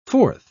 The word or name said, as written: forth